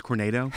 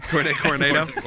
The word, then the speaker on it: Coronado: So it is the rooftop restaurant at the Grand Destino Tower of Coronado Springs. – It's actually pronounced Cornado. – Cornado. – Cornado.